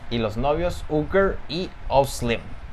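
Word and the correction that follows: Uğur: Ugur. – Ugur como Hugo. – No, Ugur. Ugur.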